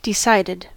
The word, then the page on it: entschieden: /ʔɛntˈʃiːdn̩/